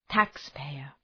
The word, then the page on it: taxpayer: ‘tæks,peıər